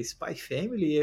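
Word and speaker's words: Spy×Family: é Spy X Family, é Spy Family, é... o correto é Spy Family, né? A gente só tira o X ali, ele não é pronunciado.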